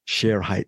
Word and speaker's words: Shere Hite: Sometimes they pronounce it, the media people, they pronounce it Shere Hite, but I think as far as the documentary goes, the correct pronunciation is Shere Hite, the disappearance of Shere Hite.